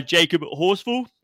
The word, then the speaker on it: Jacob Horsfall: We're joined by Jacob Horsfall.